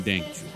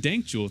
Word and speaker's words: Dankjewel: The band Dank Jewel. Thanks for coming by. Really appreciate it. – Yeah, of course. Yeah, we pronounce it Dankjewel. – What is it? – It's a Dutch word, Dankjewel.